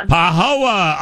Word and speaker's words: Pahoa: Is it Pahoa? – Pahoa.